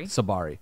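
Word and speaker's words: Tsabary: where I'm reading this book by Dr. Shefali. How do you say? – Sabari.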